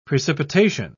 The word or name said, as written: precipitation